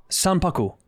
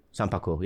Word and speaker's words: Sanpaku: I mean, I think I'm pronouncing it different, but it's Sankapul or... – Right there. – Yeah, Sankapul. – See? – So, Sanpaku. – Yeah, that's Sankapul. Sanpaku. – Sanpaku. – Sanpaku, yeah.